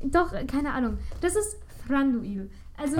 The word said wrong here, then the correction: Thranduil: Auf jeden Fall heißt. – Thranduil. – Oh Mann, heißt er Thranduil.